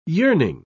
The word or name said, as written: yearning